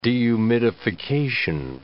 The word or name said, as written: dehumidification